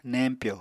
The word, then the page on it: neñpyo: Apatani